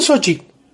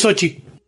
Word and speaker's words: Xochitl: No, se pronuncia Xochitl. La escuché ella misma decir su nombre y es Xochitl.